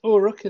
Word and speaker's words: Uruk: How do you pronounce it? – Yes, Uruk is there.